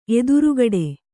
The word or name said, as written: ಎದುರುಗಡೆ